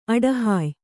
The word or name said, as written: ಅಡಹಾಯ್